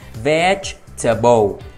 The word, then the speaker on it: vegetable: Chúng ta nhấn âm đầu tiên, veg. Rồi tiếp theo là âm T và âm E, chúng ta sẽ đọc là ter. Và cuối cùng là bầu. Vậy thì khi chúng ta ghép vào, chúng ta sẽ đọc là vegetable, vegetable, vegetable.